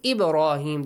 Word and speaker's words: Ibrahim: Ibrahim, the letter ba has a bouncing sound. Rahim has a heavy sound. He has a long E sound.